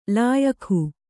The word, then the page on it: ಲಾಯಖು: lāyakhu